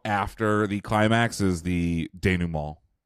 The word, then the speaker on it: denouement: So Pitchfork says, you seem as interested in the basic forms of storytelling, the way narrative elements play out toward an inevitable denouement. – Denouement. Pronounce none of the consonants, none of the vowels. Denouement. Perfect. Perfect French.